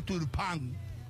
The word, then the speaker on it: Turpin: Quiero mostrar la imagen de Turpin, se escribe. Pero en francés se pronuncia Turpin.